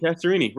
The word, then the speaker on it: Castorini: It's Loretta Castorini. Is that how you say it? Castorini? – Castorini, right.